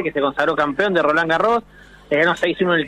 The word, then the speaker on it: Roland Garros: Roland Garros, si no sabe pronunciar, lo lamento, yo sé pronunciar bien. Esto se pronuncia. ¿No es cierto? – ¿Cómo va, equipo? ¿Todo bien por ahí? Así, está muy bien pronunciado.